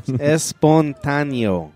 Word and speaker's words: espontáneo: No, espontáneo.